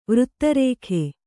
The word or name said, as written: ವೃತ್ತರೇಖೆ